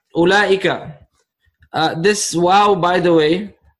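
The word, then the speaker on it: Ulaika (أولئك): And then I have finally Ooh, but this is like the wow is silent here, okay? The wow is silent. Ulaika, which means those.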